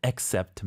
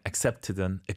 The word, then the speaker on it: accept: 이 맥락 사이 accept든 스펠링이 중요하죠. – 네네. – A-C-C-E-P-T, accept. – Accept. – Accept. – Accept. – I think it's safer to say accept.